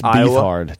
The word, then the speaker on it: Beathard: No, it's Beathard. Don't say it right or don't say it at all. – Don't say it at all. It's Beathard.